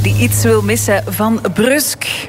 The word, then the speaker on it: Brussel: Het is met de U, zoals dat je Brugge niet uitspreekt. – Niet uitspreekt, het is gewoon Brusk.